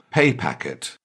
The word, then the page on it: pay packet: British English